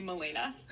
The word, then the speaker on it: Melena: And I guess all the doctors would call me Melina because they didn't know how to say it, like, properly, even though, like, it's spelled, like, how Melina would normally be spelled. And my parents got really annoyed, so they just added an A and called me Melena.